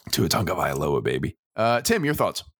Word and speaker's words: Tagovailoa: It's Tagovailoa.